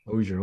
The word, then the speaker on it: Ozier: Ozier, okay. O-Z-I-E-R.